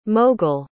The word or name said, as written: moghul